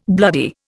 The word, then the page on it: bloody: Indian